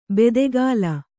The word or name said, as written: ಬಾತುಗಾಲು